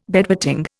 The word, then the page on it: bedwetting: Indian